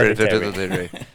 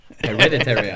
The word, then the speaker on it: Hereditary: Men, men, den filmen vi var såg, som jag aldrig kan uttala titeln till, Her Hereditary. – Hereditary. – Hereditary, ja.